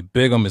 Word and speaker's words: Bigham: But you can find me on Facebook under my name, Rodney Bigham. It's spelled B-I-G-H-A-M.